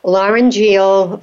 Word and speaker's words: laryngeal: I'm not sure I'm pronouncing it correctly. Laryngeal reflux. So you'll have to correct me and tell us about that. – No, perfect. It's absolutely perfect.